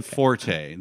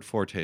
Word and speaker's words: forte: Forte. The word forte is not actually pronounced forte. It's pronounced fort.